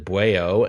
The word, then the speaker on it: Bwao: Calloway, the brainchild of brothers Reggie and Vincent Calloway, reuniting their talents on their latest track entitled Bueo, spelled B-W-A-O, but they pronounce it Bueo,